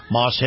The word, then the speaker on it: Maus: Moss has one of the largest selections anywhere in the state. And before you buy elsewhere, see Moss. Now, Moss is spelled M-A-U-S.